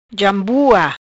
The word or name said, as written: Jambua